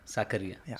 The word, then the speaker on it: Sakariya: चेतन साकरिया, कैसे आप प्रोनाउंस करते हो लास्ट नेम आपका? – साकरिया. – साकरिया, सो I was right. – Yes, perfect.